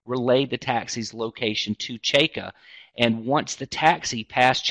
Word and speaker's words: Cejka: He had earlier, he sought assistance from a Maine State Police trooper named Robert Cheka, and C-E-J-K-A, it's pronounced Cheka, who Cheka is in uniform, and he's driving a marked police cruiser.